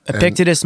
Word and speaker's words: Epictetus: Epictetus, Marcus Aurelius.